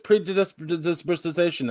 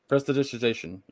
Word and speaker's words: prestidigitation: I didn't know I could just predisposition of that off. – Prestidigitation.